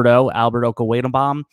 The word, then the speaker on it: Okwuegbunam: is Albert Okwuegbunam on Denver. – Dude, your pronunciation of that name was awesome. Did you practice that? There's no way I was going to say it that way.